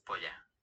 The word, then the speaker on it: polla: So, un pala grande. – Polla. – Polla. – Double L's pronounced as a Y.